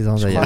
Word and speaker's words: Zendaya: C'est Zendaya. – Ah, c'est un A vraiment. – Non, c'est un E, mais faut ça dire se prononce Zendaya.